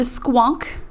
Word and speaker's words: Squonk: If anyone wants to know, it is spelled S-Q-U-O-N-K.